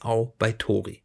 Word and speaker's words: Outbaitori: Und äh, dieser Gedanke, dieses Konzept ist das heißt, ich, und ich fürchte, ich werde es jetzt ganz falsch aussprechen, aber ich spreche jetzt aus als Au bei Tori.